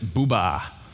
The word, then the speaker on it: Buber: Just to do him credit, if I'm a good German, I would pronounce it Buba. So Martin Buba, okay?